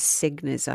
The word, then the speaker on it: Cegniz: September 21, Judith, her surname is Segniz, I think it's pronounced, C-E-G-N-I-Z. She was arrested and charged with the murder of her twin brother.